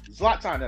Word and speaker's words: Zlatan: Zlatan, Zlatan, I know this is how to say his first name.